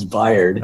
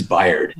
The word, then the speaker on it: Bayard: Is it Bayard or is it Bayard? – It's Bayard. – Okay. – But it is one of those things that it's probably like your last name, Jason, that I've had it mispronounced my whole life. I don't even recognize it anymore, but it is Bayard.